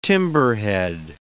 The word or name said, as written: timberhead